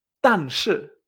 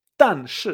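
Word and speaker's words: tan shi (但是): Pero en chino se dice tan shi, tan shi, ¿eh? como pam, pam, dos tiros.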